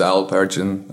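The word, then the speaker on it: Alp Ergin: My name is Al Pergin and my co-founder is Hazel Topçu.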